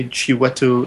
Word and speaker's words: Chiwetel: I think everyone knows that I'm a fan of Steve McQueen and Michael Fassbender and Chip Patel Ejiofor. Did I get that right? Did I nail that? – I say Chiwetel Ejiofor.